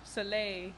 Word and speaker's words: Soleil: Soleil? Soleil? Why are you laughing at me? – It's French. Soleil.